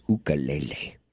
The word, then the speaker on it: ukulele: Well, don't shoot the messenger, Deb, but it's pronounced ukulele.